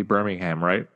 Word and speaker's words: Birmingham: You see Birmingham, right? Like it's Alabama. It's not pronounced the same way, right? – Yeah, just kind of the Americans.